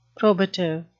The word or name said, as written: probative